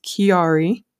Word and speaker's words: Chiari: Yes, it's pronounced Chiari.